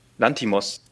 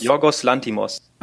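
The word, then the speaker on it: Yorgos Lanthimos: Von äh, Giorgio Latimos. Ich habe keine Ahnung. Er ist Franzose wahrscheinlich. Ich kann den Namen nicht aussprechen. Kann, kennst du ihn? Giorgio äh, Latimos? Lantimos. Lantimos? Jorgos Lantimos.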